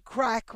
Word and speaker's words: craic: as you would say in Ireland, good crack, which does not mean good drugs. It means, it's spelled C-R-A-I-C, and it means, having a good time, laughing, telling stories, sharing jokes, enjoying one another's company and having a lot of laughs.